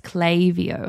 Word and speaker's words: Klaviyo: If you are in e-commerce land, I highly suggest that you jump on the Klaviyo. They may have had a bit of a moment with me on Threads last night because I was like, it's Klaviyo. And they're like, no, it's Klaviyo.